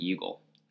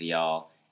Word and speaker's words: Youghal: In Y'all, which is not pronounced Yugle. – It's spelled Y-O-U-G-H-A-L for those who aren't aware. – That's right. But it's definitely pronounced Y'all, as we heard.